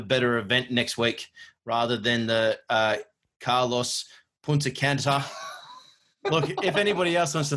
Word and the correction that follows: Punta Cana: So it's the Carlos Punta Cana. – Punta Cana. Is that how you say it? – Yes.